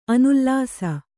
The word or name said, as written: ಅನುಲ್ಲಾಸ